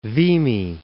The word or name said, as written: Vimy Ridge